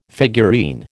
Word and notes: figurine: American English

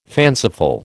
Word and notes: fanciful: American English

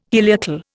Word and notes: heliacal: Indian

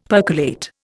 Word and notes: percolate: Indian

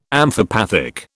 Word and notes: amphipathic: British English

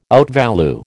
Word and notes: outvalue: American English

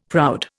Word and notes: proud: Indian

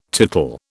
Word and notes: tootle: British English

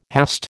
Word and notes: hast: American English

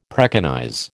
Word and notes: preconize: American English

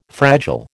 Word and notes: fragile: American English

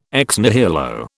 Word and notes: ex nihilo: British English

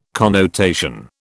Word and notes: connotation: British English

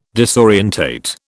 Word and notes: disorientate: British English

